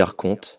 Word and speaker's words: Arconte: Déjà, première question, c'est bien comme ça que ça se prononce Bonne question. Euh, moi, je toujours dit Arconte. Après, euh, faudrait demander au, principal intéressé. Très bien. Bon. – Mais, Arconte.